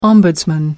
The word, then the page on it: ombudsman: American English